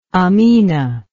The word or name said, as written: aminah